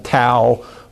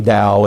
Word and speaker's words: Dao: Until recent years, when that word was translated into English, it was often spelled with a T, like Tao, but it's actually Tao, and now in most modern English writings, it's spelled D-A-O.